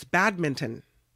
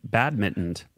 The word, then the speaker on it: badminton: It's not. It's badminton. M I N T, like mint, badminton.